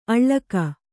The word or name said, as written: ಅಳ್ಳಕ